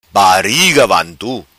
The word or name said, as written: vantu bariga